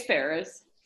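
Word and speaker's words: Ferrars: Did you just say Mrs. Farrar? – I don't know how to say it. – It's Ferris. – Ferris, okay. There's an S.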